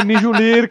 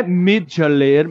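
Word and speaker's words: Mjölnir: pronúncia certa é Mijulir, cara. O martelo do Thor. Eu vou ensinar para vocês que estão ouvindo esse podcast. Não é Mijulir, Mihaler, é Mijulir.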